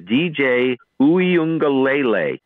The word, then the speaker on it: DJ Uiagalelei: His name is DJ Uiagalelei.